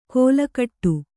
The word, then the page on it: ಕೋಲಕಟ್ಟು: kōlakaṭṭu